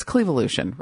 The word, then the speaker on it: Clevolution: It's spelled C-L-E. Is it Clevolution or... It's Clevolution, right? C-L-E-V-O-L-U-T-I-O-N. The Clevolution campaign.